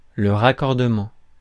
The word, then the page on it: raccordement: [ʁa.kɔʁ.də.mɑ̃]; France